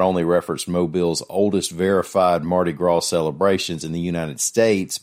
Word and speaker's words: Mardi Gras: Mayor Stimpson also knows the correct Alabama way to say Mardi Gras is that it rhymes with Tim McGraw.